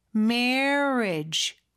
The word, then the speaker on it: marriage: 그리고 결혼이라는 marriage 요거는 marriage 이렇게 돼 있지만 may하고 re 사이에 어가 살짝 숨어 있다고 생각하면서 입모양을 그렇게 만들어 주세요. marriage 그렇게 발음해 주시면 조금 더 원어민처럼 하실 수 있을 거예요.